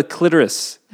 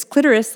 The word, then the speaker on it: clitoris: I have a clitoris. Clitoris instead of clitoris. – Yeah, which rhymes with Dolores.